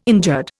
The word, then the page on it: injured: Indian